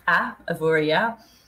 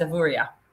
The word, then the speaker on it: Avoriaz: Is it pronounced Avoriaz or Avoriaz? – Officially, it should be Avoriaz, but that does depend who's saying it. But yeah, it is Avoriaz. – But if you were saying it right, okay, it's Avoriaz. Yeah.